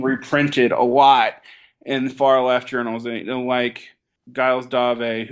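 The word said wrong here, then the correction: Gilles Deleuze: who I'm probably mispronouncing his name. Your European listeners can cringe every time I say anything French. – Gilles Dove. – Yeah, Gilles Dove.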